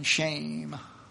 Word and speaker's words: Shem: Hebrew pronunciation agreed upon, shame.